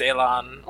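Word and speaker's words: élan: Whatever you do with efficiency, grace, and is it pronounced Elan? – Yeah, I believe it's Elan.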